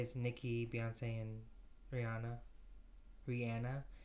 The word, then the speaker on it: Rihanna: That's how you say her name. Rihanna. – No. – Yeah. – Rihanna? – Rihanna. – No. – Look it up. – How? – That's what she says. She says... – I want to look up her pronouncing her own name. – Yeah, she goes, it's Rihanna.